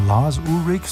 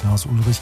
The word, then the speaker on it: Lars Ulrich: Und da wissen wir, Moment, da gibt's ja Lars Ulrich, so wird er ja ausgesprochen, nicht Lars Ulrich, den Drummer von Metallica.